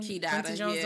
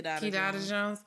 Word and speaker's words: Kidada: And apparently, because you know he was with Kadida Jones, right? That's how you pronounce her name? – Kidada Jones. Yeah. – Kidada Jones.